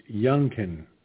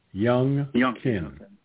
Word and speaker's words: Youngkin: Well, by the way, it's not Duncan, it's Youngkin. Youngkin. – Yeah. – Which rhymes with Duncan, as in donuts.